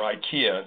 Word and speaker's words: IKEA: He was a pioneer in this area, one of the first people doing automation and robotics in IKEA, or IKEA, depending on how you pronounce it, in the Netherlands.